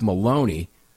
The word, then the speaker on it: Maloney: In a memo to the court, Assistant Attorney General Eric Maloney, which rhymes with baloney, wrote that Fleet Farm violated the Minnesota Gun Control Act by, quote, transferring firearms to straw purchasers.